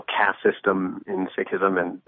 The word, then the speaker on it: Sikhism: Yes, it's actually Sikhism.